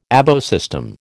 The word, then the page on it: ABO system: American English